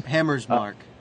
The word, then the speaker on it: Hammersmark: And it's like this is something as trivial as clothing that they're worried about, and that this awfully risky maneuver by Bridget von Hammersmack, or I can't remember how to pronounce her name. – Hammersmark. – Right, Hammersmark, that's right.